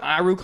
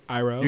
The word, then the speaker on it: Iroh: You got the fucking Zuko and Iroh come there, Aang and him fighting the city. – Oh, well, say his name right, Iroh. – You gotta respect Iroh. OG. – What did I say? – Iroh.